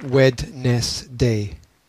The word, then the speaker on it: Wednesday: Wednesday, which I had to remember as Wednesday, because the spelling was just so weird. Because you don't pronounce the D, you just say Wednesday.